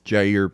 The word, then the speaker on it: Jair: It is Jair Bolsonaro. Hard J.